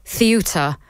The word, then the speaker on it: Ceuta: Right, let's now turn our attention to, I've just been told the actual correct pronunciation is Ceuta.